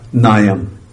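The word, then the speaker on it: Nayam (नायम्): Nayam. N-A-Y-A-M. Long A.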